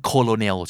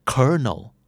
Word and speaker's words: colonel: จากหน้าตาเนี่ยน่าจะออกเสียงว่า colonel ใช่ไหมฮะแต่จริงๆคำนี้อ่านว่า colonel